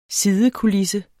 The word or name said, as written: sidekulisse